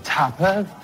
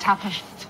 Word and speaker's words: tapas: Do you guys tapas? – Oh, no, it's actually pronounced tapas.